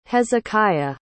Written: hezekiah

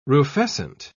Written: rufescent

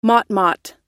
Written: motmot

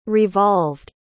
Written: revolved

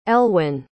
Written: elwen

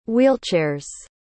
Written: wheelchairs

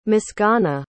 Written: misganna

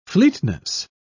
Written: fleetness

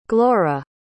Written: glora

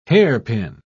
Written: hairpin

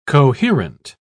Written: coherent

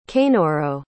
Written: Kanoro

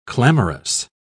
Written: clamorous

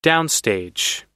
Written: downstage

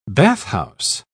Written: bathhouse

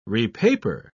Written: repaper

